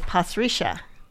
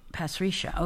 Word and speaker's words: Pasricha: Well, I'm not sure how to pronounce his last name. – I'd say Pasricha. – Pasricha, okay, yeah.